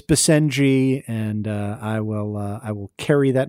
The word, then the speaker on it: Basenji: In TTTB 64, that was dog law, I mispronounced the name of the breed, which is apparently pronounced Basenji. I think I called it Basenji.